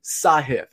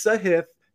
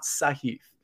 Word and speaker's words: Sahith: It is Sahith. And I don't mean to be the pronunciation police, but we keep saying his name. He's a rising star, in my opinion. We may as well get it right. It's Sahith, not Sahith.